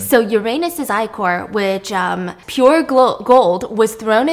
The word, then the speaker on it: ichor: Uranus's ichor is, I know some people pronounce it ichor, but I'm pretty sure it's ichor because I went to like all these like Greek pronunciation websites.